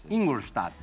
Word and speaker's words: Ingolstadt: pro vamos ver se a minha pronúncia tá correta. Ingolstadt, é isso? Isso. Ingolstadt.